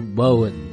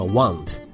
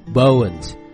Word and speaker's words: won't: 在这里呢，这个词读 won't，有一个 o 的发音，不要读成了 want， won't。